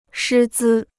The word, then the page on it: 师资: shī zī